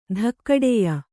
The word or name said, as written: ಧಕ್ಕಡೆಯ